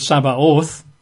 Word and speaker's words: Sabaoth: Hosts, actually is a Hebrew word, Sabaoth. in Hebrew that's, well, in English that would be T-S-A-B. A-O-T-H, Sabaoth, T-S-A-B, A-O-T-H. So it says Lord of Hosts or Lord of Sabaoth, the underlying Hebrew.